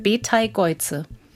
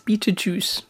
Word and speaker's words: Betelgeuse: Wir haben eine Aussprachedatenbank in der ARD und da steht drin, man spricht den Stern Betelgeuse. Widerstrebt mir total, aber du hast noch eine andere Aussprache. – Nee, ich kenne den auf Englisch halt als Betelgeuse. Aber, Betelgeuse. Ja. – Also sucht es euch aus. – Genau. – Ich finde Betelgeuse schön, so kannte ich's.